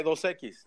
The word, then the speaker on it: Dos Equis: You're saying it right, Dos Equis. You're just Dos Equis. – Okay, cool. – You're just saying it too slow.